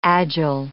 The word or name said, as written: agile